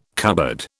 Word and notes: cupboard: British English

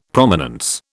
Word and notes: Prominence: British English

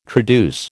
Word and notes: traduce: American English

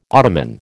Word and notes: Ottoman: American English

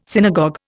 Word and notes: synagogue: Indian

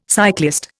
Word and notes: cyclist: Indian

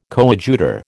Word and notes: coadjutor: American English

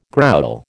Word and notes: growl: American English

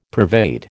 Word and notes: pervade: American English

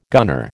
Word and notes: gunner: American English

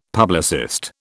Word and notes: publicist: British English

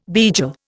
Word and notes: beigel: Indian

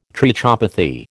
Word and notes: trichopathy: American English

